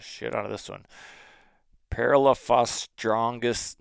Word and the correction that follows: Parelaphostrongylus: Wait, nope, already screwed it up. Parelaphostrongylus tenuous.